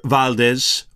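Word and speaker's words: Valdes: Now, Peter Valdez, or you'll also see his name often depicted as Valdo, W-A-L-D-O, but it wasn't pronounced Waldo, most likely Valdez.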